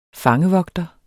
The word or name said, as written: fangevogter